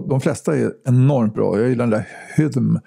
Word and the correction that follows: Hymn: Hydm jag, kan, jag kan inte uttala det. Hymn, Hymn heter det.